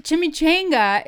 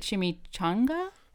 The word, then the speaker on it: chimichanga: Well, chimichanga is the way we discovered that that's how you say it in the Boston area. And in certain other areas like Arizona, they say it chimichanga.